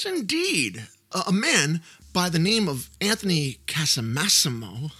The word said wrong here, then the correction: Casamassima: Did I say that right, Chris? – Casamassima, all right.